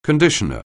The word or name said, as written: conditioner